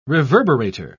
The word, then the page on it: reverberator: British English